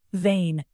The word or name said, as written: vein